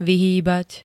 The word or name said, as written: vyhýbať